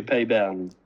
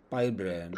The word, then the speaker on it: Peybernes: Pay pronunciation would be Payburn. Payburn.